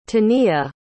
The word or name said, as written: taniya